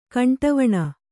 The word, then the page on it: ಖಂಡವೀಣೆ: khaṇḍa vīṇe